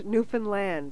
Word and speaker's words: Newfoundland: Because we say Newfoundland, we say different things. No, it rhymes. Understand Newfoundland.